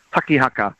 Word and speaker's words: tangihanga: here's a classic example is tangihanga, and of course down in kaitahu, they don't pronounce the NDs, they turn it into a K, so it becomes takihaka.